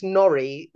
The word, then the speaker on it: Norry: Because some people spell it N-O-R-R-I-E. – Yeah. – And I just end up having to say to people, oh, it's NORRY, like it rhymes with lorry.